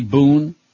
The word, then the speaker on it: Boone: Debbie Boone rhymes with spoon and moon and June and coon, and we hate Debbie Boone.